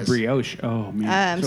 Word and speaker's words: brioche: B-R-I-O-C-H-E. – Brioche? – Brioche? – Brioche.